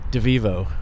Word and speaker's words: Devevo: Devevo.